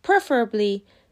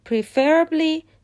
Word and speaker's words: preferably: تلفظ درست preferably استرس روی سیلاب اوله و در تلفظ غلط preferably استرس روی سیلاب دومه پس درستش میشه preferably